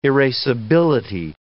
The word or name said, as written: erasability